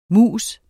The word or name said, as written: MUS